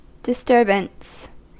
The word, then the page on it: disturbance: American English